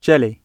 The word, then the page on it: jelly: British English